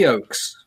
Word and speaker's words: Hollyoaks: Holly Oates. What is that? Is that did I say that right? You were on Holly, Holly Oaks. Hollyoaks.